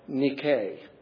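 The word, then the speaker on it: Nike (νίκη): Now, it may interest you to know that the victory here is pronounced in the Greek something like Nike. The what we would say as Nike.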